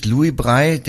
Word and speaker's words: Braille: Genau, und Sie sind nicht Louis Brei, der ja so ausgesprochen wird, sondern Sie sind Ralf Brei.